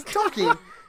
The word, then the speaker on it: Tolkien: He didn't say Tolkien. He's Tolkien. – It's Tolkien. Yeah.